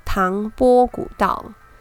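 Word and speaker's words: 唐蕃古道: 唐朝的时候呢，诶，青藏茶马古道，它有一个别名叫做唐波古道。这个“ 波” 字呢，它其实是番薯的“ 番” 那个“ 番” 字，它的破音在古音呢念作“ 波”。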